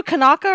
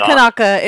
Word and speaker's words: Kanaka: Are you a Kanaka or a Howley? – Well, first off, I think the word you're trying to pronounce is Kanak. – No, it's Kanaka. It's Kanaka, dude.